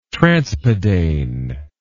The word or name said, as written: transpadane